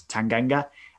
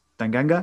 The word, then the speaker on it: Tanganga: Tanganga says, can you tell me your name properly? Because I hate when people pronounce my name wrong. And he says, it's Tanganga. And he says, Tanganga? And he says, yeah, Tanganga.